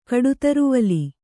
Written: ಕಡುತರುವಲಿ

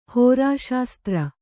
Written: ಹೋರಾಶಾಸ್ತ್ರ

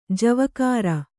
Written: ಜವಕಾರ

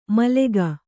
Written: ಮಾಲಕ

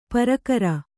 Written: ಪರಕರ

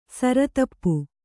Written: ಸಿರಿತುಪ್ಪೆ

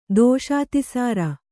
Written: ದೋಷಾತಿಸಾರ

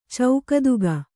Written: ಚೌಕದುಗ

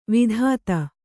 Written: ವಿದಿತೆ

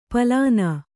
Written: ಪಾಲನ

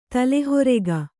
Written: ತಲೆಹರಿಗೆ

